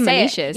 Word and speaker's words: monoecious: Like, I thought you were mispronouncing it, but that's how you say monoecious.